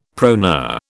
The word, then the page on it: proneur: British English